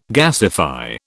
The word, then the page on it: gasify: British English